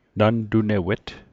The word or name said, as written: nantunewet